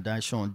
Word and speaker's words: Dyshawn: Then Dyshawn, D-Y-S-H-A-W-N.